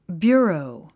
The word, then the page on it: bureau: bjú ə rou; ビュゥロウ